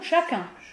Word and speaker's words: chacun: Chacun. Ah, on sait à quel endroit? Non, tu prononces pas la consonne finale, ni le S avant le C.